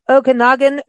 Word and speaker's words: Okanagan: And then, speaking of golf, so your destination is the Okanagan Valley. Did I pronounce that correctly? – Yes, you did.